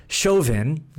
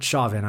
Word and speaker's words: Chauvin: George Floyd died after Minneapolis police officer Derek Chauvin, not Chauvin, I was mispronouncing it a few streams back.